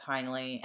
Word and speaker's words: Heinly: Yes, you're listening to Two Hoes from Delco with Beth Heinley and Maureen Cummings. My name is spelled B-E-T-H. My last name is H-E-I-N-L-Y, and Maureen's name is M-A-U-R-E-E-N.